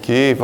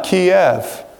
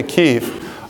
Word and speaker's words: Kyiv: Kyiv. All right. Not Kiev. That's the Russian way to pronounce it. We don't pronounce it that way anymore. Okay. We say Kyiv.